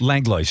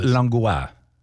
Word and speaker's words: Langlois: How do I say it in French? Langlois. Je m'appelle Langlois.